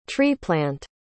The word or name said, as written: tree-plant